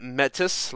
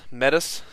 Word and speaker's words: Metis: To Dayton Metis, I am sorry that I mispronounced your name. Thank you for people from North Shelby for helping me fix that. Metis, like Fetis, Metis.